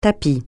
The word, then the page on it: tapis: francais